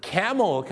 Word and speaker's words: camel: อูฐก็ต้องออกเสียงว่า camel ครับผม camel ไม่ใช่คาร์เมลนะฮะ